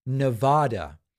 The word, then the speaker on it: Nevada: And then Howie says, love your stuff, but it's pronounced Nevada, not Nevada. I can't seem to get it right. And part of the problem is that this is a word that's in Spanish, which means a snowstorm. Nevada.